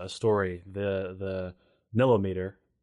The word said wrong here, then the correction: nilometer: uh, story of oh the nilometer.